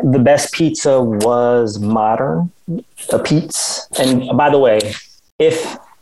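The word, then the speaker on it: apizza: And it's pronounced a pizza.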